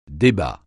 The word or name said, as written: débat